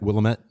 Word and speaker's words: Willamette: I put experimental Willamette Valley red wine. – And that is not a good sales tool. – Am I saying that right? Willamette.